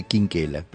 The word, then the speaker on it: Quinquela: No, no, por eso se escribe por ahí Chinchella, pero se dice Quinquela.